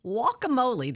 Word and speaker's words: guacamole: Guacamole. The G is silent. – The G turns into a W.